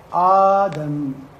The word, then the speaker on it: آدم: شوف ثم نقلب الهمزة الثانية الساكنة ألفا فيصبح الاسم على هذه الهيئة آدم. نطق آدم مثل نطق النداء آدم. والسبب في قلب الهمزة الثانية ألفا